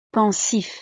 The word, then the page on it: pensif: frances